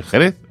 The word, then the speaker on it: Xerez: El equipo de fútbol de que le diremos Xerez, realmente se escribe Xerez porque es J. Él se dice Jerez, aunque pone escrito el equipo con X.